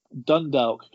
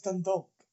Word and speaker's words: Dundalk: Yeah, that was literally Dundalk going to the Camp Nou. – Dundalk, no, it's Dundalk.